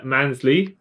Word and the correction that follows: Maslany: Yeah. – Maslany. – Yeah, Maslany.